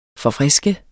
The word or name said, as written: forfriske